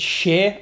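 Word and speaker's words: Schär: Well, apparently, because it's German-Swiss, it's Shea, as in, you know, like, Shea, Sonny and Bono.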